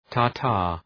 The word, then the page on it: tartar: ‘tɑ:rtər